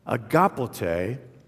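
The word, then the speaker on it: agapote (ἀγαπᾶτε): In the original Greek, there's a verb here, and it's pronounced agapote, agapote, and that's the verb, of course, where we get the noun agape.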